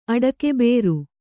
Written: ಅಡಕೆಬೇರು

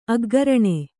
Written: ಅಗ್ಗರಣೆ

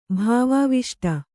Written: ಭಾವಾವಿಷ್ಟ

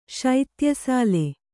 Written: ಶೈತ್ಯಸಾಲೆ

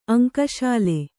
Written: ಅಂಕಶಾಲೆ

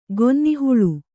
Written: ಗೊನ್ನಿಹುಳು

